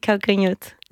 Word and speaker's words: Calcanhoto: Calcanhoto ? – Oui, Calcanhoto.